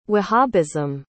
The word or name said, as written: wahhabism